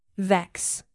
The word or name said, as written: vex